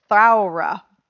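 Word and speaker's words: Thowra: It's E-L-Y-N-E. Elaine Mitchell. It's about a wild horse, or Brumby, called Throra. The O is pronounced as an owl. Okay, this entire paragraph is like, you don't know how to say these words. Throra.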